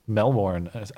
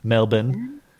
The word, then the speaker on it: Melbourne: There was chaos or mild chaos that happened at her Melbourne. Sorry, I always say it wrong. They get pissed off at me. – Melbourne.